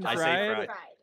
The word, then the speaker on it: Fried: We were talking about how to pronounce his name. Fried? Chicken fried.